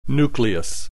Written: nucleus

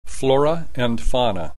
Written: flora and fauna

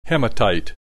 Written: hematite

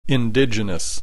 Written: indigenous

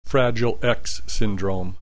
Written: fragile X syndrome

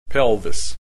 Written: pelvis

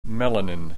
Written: melanin

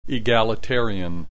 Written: egalitarian

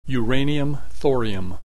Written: uranium thorium

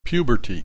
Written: puberty

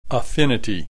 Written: affinity

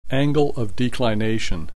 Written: angle of declination

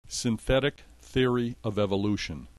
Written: synthetic theory of evolution

